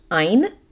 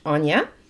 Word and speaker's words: Aine: It's spelled A-I-N-E, and for the longest time, I thought it was Ein, but it's apparently pronounced Anya.